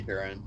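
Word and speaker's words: Húrin: Is it Hurin? Am I saying that right? – I say Hurin. – Yeah, and... Hurin.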